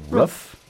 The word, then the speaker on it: roof: Okay, pronounce this word. I'm going to spell it. – Yeah. – G-O-O-D. – Good. – Good. R-O-O-F. Rough. Good. Good. Rough. Rut. Good. – Roof. – It's the same vowel sound as good.